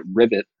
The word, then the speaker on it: Rivyt: I guess you pronounce it Rivyt, R-I-V-Y-T.